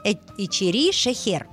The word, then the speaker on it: Ичери-Шехер: Ичери-Шехер. Я не знаю, Шехер. Ичери-Шехер. Да, меня научили. Ударение на последний слог во всех азербайджанских словах ставится.